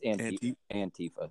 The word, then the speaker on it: Antifa: Yeah, it's Antifa.